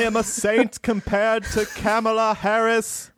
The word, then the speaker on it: Kamala: The pronunciation is Kamala. – Kamala. – Kamala Harris.